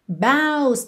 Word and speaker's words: boughs: Boughs também é uma palavra que tem uma pronúncia um pouco tricky. B-O-U-G-H-S. Boughs, que significam os ramos, ok?